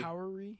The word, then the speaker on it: Howery: Well, this movie is starring Eric Andre, Tiffany Haddish, Little Rel Howery. Is that how you say it? Do you know? – Howery.